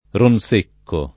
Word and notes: Ronsecco: lingua italiana

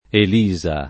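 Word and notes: Elisa: italiano

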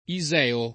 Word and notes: iseo: lingua italiana

